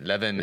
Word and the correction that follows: Levan: Levan Chokheli. Chokheli. There you go.